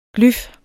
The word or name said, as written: glyf